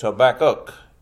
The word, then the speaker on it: Habakkuk: Most of us mispronounce that book and call it Habakkuk. It's Habakkuk, K-U-K.